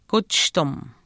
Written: kuchishtim

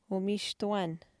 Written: umiishtuwaanh

